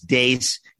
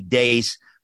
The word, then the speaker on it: Deace: And now that I know how to pronounce Dace, D E A C E, Dace, but where can people find you?